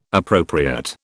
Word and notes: appropriate: British English

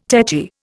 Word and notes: techy: Indian

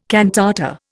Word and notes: cantata: Indian

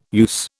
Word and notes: use: British English

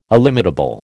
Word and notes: illimitable: American English

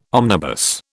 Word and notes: omnibus: British English

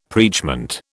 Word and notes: preachment: British English

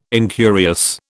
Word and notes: incurious: British English

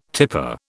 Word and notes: tipper: British English

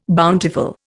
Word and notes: bountiful: Indian

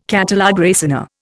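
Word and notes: catalogue raisonné: Indian

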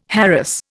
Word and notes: harass: Indian

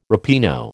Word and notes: ripieno: American English

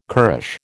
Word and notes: currish: American English

